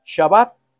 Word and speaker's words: Shabbat: Ambos se pronuncian igual, Shabbat y Shabbat, el sustantivo y el verbo.